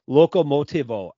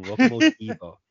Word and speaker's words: Locomotivo: Locomotivo.